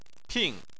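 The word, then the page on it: ping: tone 4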